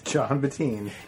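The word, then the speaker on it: Jon Bettin: John Bettine. – And Bettine is spelled B-E-T-T-I-N.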